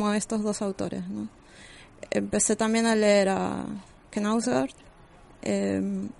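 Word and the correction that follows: Knausgård: Knauser, Así se pronuncia. Knausgord.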